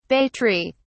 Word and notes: bay tree: British English

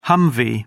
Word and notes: humvee: British English

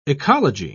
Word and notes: oecology: British English

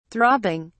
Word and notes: throbbing: British English

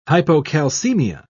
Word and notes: hypocalcemia: British English

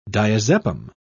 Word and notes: diazepam: British English

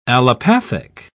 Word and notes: allopathic: British English

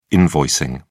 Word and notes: invoicing: British English

